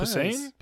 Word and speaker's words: Posehn: That's Posen? Right, Posehn? Posen? I don't I actually I know how to say his uh, name. – Po- uh, Posehn, I believe.